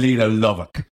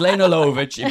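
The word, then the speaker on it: Lovich: If that was me, I'd be like, oh, what do you mean, Lena Lovick? That you're reading that. It's pronounced Lena Lovitch.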